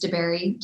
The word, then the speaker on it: DeBerry: Sometimes you have to put in my maiden name for it to pop up, which is DeBerry, D-E-B-E-R-R-Y.